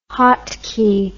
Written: hotkey

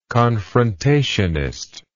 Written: confrontationism

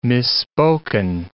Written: misspoken

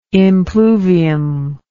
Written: impluvium

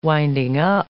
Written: winding-up

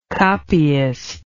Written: copyist

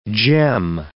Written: gem

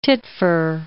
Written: titfer

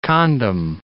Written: condom

